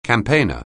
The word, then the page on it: campaigner: anglais